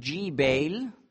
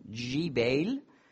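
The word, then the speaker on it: Jbeil: Und Gebal, arabisch Jibail, das G wird weich ausgesprochen, Jibail, das ist diese Stadt, die früher Byblos hieß, auch im nördlichen Libanon.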